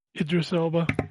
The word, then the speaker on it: Idris Elba: Is it Idris Elba? Idris Elba? – Idris Elba.